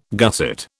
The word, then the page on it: gusset: British English